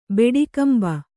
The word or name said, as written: ಬೆಡಿಕಂಬ